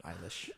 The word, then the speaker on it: Eilish: Like eyelash, but Eilish.